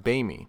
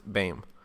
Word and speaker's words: Bayme: But anyway, as regard to the correct pronunciation of my last name, if you are Dan White, Doug McKenzie, or David Blaine, or pretty much anyone else in the New York area, my last name is pronounced Bayme. However, if you exist in the real world and like proper pronunciation, my last name is properly pronounced Bayme.